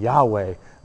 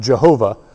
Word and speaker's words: Yahweh: And he gets this this inhale, exhale, this yod, he, vav, he response, which is Yahweh, yod, he, vav, he, Yahweh, mispronounced Jehovah later on.